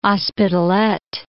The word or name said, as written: Hospitalet